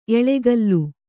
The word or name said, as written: ಎಳೆಗಲ್ಲು